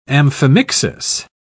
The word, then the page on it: amphimixis: British English